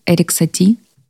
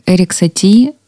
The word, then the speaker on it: Ерік Саті: Ерік Саті. І правильно вимовила? – Так. – Мільйон раз перепровіряю, кожен раз боюся помилитися в цьому. Так, Ерік Саті, в честь нього вона обрала свій позивний.